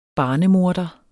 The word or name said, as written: barnemorder